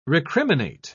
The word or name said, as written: recriminate